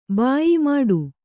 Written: ಭಯ್ಯಾ

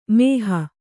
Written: ಮೋಹ

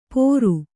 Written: ಪೋರು

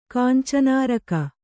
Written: ಕಾಂಚನಾರಕ

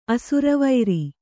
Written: ಅಸುರವೈರಿ

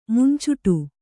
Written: ಮುಂಚುಟು

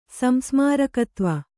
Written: ಸಂಸ್ಮಾರಕತ್ವ

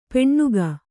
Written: ಪೆಣ್ಣುಗ

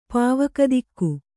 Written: ಪಾವಕದಿಕ್ಕು

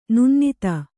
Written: ನಿನ್ನದು

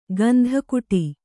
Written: ಗಂಧಕುಟಿ

